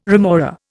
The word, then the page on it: remora: Indian